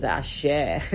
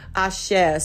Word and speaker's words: axé: For those of you that are listening to this, it's like, what the hell is axé? Axé spelled axe. It's not the body spray, okay? It's A-X-E with a high accent on the E. Axé. Axé is the energy that we build in capoeira.